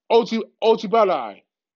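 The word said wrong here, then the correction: Ojulari: Ojolei. Excuse me, y'all. AZ Ojolei.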